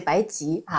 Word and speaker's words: 白及: 那么我们就把它按成及格的及，从中国药典上是没有草字头的。 – 好、嗯、好，那就白及。